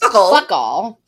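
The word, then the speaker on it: FOCL: When their library becomes in danger of being shut down due to budget cuts, the friends of the Chalcott Library, or Fockle. – It sounds like Fuckle. Pronounced Fuckle.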